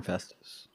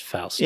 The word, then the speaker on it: Faust: But they called him Festus. – They did kind of call him Festus, I guess. But yeah, I think it's supposed to be pronounced Faust. – Yeah, it's like Faustus.